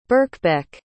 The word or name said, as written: birkbeck